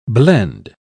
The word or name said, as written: blend